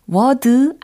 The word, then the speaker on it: word: W O R D라는 철자. word word word 네. word 아니고 word 미국식 영어로 연습을 하는 겁니다.